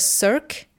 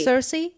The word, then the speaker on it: Circe: I just read Cirque. Is that how you say it? – Circe.